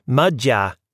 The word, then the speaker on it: Magyar: My grandmother sang me old songs in the Magyar, pronounced Magyar language, learned from her aunt, who never married because her sweetheart had been killed fighting the Serbians.